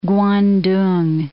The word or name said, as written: Kwantung